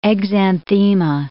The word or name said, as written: exanthema